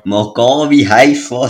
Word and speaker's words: Maccabi Haifa: Äh, weil man hat gesehen, äh, Magai Haifa, wenn ich es richtig ausspreche. – Maccabi äh, Haifa, aber ja. – Maccabi Haifa, so.